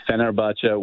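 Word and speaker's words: Fenerbahçe: And they're Fenerbahce, local, yeah.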